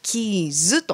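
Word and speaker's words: KEYES: さあ、野依さん、この会社名なんですけれども、はい、アルファベットで KEY ES と書いてキーズとなっています、はい、 KEY が大文字、そして小文字で ES、はい、と、ね、書かれているんですけれども、はい、どんな会社なんでしょうか。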